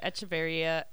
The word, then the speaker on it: Echeverria: Echeverria, if you want to roll the R.